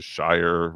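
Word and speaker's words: Scheyer: So my genius of the week is Kyle Filipowski, which also may trickle down to head coach, whatever his name is, Shire, rhymes with Cryer.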